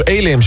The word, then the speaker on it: Elim: Oh, Elim, zo spreek je het uit. Elim, ja, het is zo klein, ik zit er te kijken waar het ligt.